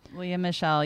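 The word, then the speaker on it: Lea Michele: Leah Michelle. Is that how you say her name? – Leah Michelle, yeah.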